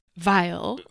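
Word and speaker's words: Viall: So there's one, Charlie was on, Nick Vile. – Right. Yep. That's how you say his name.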